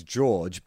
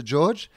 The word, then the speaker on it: Jorge: the, the, official pronunciation might be Jorge, but this is bloody Australia, mate, and it's Fantasia, and it's fucking George, mate.